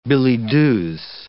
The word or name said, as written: billets-doux